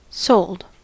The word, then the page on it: sold: American English